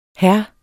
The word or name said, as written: hr.